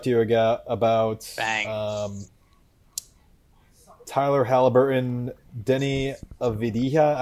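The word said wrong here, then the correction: Avdija: I don't know how to say it. He's the. – Avdia.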